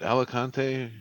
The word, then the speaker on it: Alicante: You know, gonna get the Airbnb at the in Alicante. – Mm-hmm. – Is it Alicante? Am I saying that right? – Yeah.